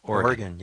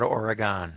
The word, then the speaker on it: Oregon: That's the correct pronunciation, you know. – Oregon, yes. Not Oregon.